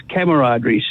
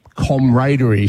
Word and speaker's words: camaraderie: But I suppose really in English it should be comradery, shouldn't it? But we just don't say that.